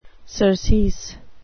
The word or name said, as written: surcease